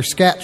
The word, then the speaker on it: Skatt: It's spelled S-K-A-T-T, so they're scat chips. – S-K-A-T-T, wow.